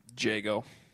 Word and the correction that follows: Django: Django. – I mispronounced his name.